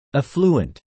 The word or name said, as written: affluent